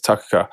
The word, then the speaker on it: Takakā: Because I looked at it and went Takakā, but we kind of emphasized the second syllable. Yeah. They get into the last one, so it's Takakā.